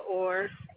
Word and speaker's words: Orr: Micah Orr. – Spell it for me. – M-I-C-A-H. My last name's O-R-R.